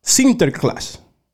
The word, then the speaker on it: Sinterklaas: San Nicolás en el idioma de ellos, por aquí que yo lo escribí, se pronuncia Sinterklaas.